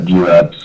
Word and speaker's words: Juab: Yep, Jueb.